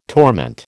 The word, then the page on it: torment: American English